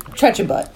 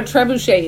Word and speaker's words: trebuchet: Like, you know, when I try to say Trechabut. – So you just read that wrong.